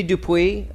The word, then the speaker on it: Dupuis: Sadie Dupuis, is that how you pronounce the last name? – Yes, that's it.